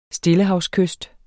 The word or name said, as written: stillehavskyst